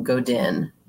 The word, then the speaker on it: Godin: And it's by a gentleman, Seth, and I think you pronounce his last name Godin, G-O-D-I-N.